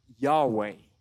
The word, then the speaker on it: Yahweh: But, um, you know, scholars, they, there's a consensus that says, like, probably these four letters were spelled like Yahweh.